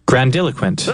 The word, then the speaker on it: grandiloquent: I'm not sure if I accented the proper syllables there in my pronunciation. Grandiloquent. – Grandiloquent. – I did it right.